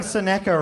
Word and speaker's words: Seneca: Am I saying Seneca right? Nailed it.